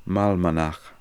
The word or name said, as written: Malmanakh